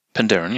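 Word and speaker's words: Penderyn: Pendaren, ne, spricht man das aus, oder? – Pendaren, ja.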